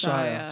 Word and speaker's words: Shia: But am I saying his name right? – Shia I believe LaBeouf. It's Shia.